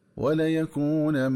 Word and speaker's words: وليكونا: وكذلك ينبغي التنبه للنطق الصحيح للفظ ولا يكون، ففي حالة الوصل يقرأ بفتح اللام ونون أولى مخففة مفتوحة ونون ثانية مفتوحة تدغم في الميم هكذا ولا يكون من الصاغرين.